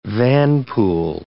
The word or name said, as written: vanpool